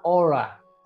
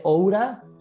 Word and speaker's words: Oura: Eh, y este anillo se llama Aura, Aura Ring. Se escribe Oura, O-U-R-A, Ring, anillo Oura.